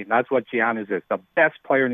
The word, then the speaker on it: Giannis: I think we should teach you how to say his name. – It's Giannis. – Okay. – Giannis, Giannis like with a Y. Giannis.